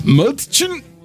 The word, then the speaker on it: Mädchen: And forgive me if I pronounce it wrong. Mädchen. – Very good.